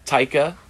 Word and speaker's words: Taika: Taika Waititi. – Damn it, I was so close. So close. – You were not close. You were not close. – Taiki Waititi. – Taika.